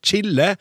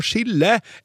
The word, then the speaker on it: chille: Istedenfor å si chille eller å spise chili, så sier mange nordmenn å chille eller å spise chili med samme SJ-uttalelse som i sjokolade!